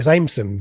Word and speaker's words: Amerson: And then a younger guy called Will Amerson has played the 9 appearances. Oh, is that my first dodgy pronunciation of the evening? – I think it's Ameson, but anyway. – Okay, what did I say? Amerson, all right.